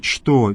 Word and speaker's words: что: Слово «что» надо произносить именно так, через «че», а не как мы в русском языке говорим «что».